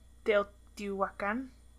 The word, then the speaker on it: Teotihuacan: Teotihuacan. Teotihuacan. You don't pronounce the H.